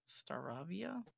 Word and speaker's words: Staravia: And then Staravia? Staravia? – Yeah, I believe actually you said that right.